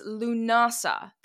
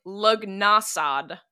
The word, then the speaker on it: Lughnasadh: All right, it is L-U-G-H. N-A-S-A-D-H. So phonetically in English, it looks like Lugnasad. But luggily for us, it's just pronounced Lunasa.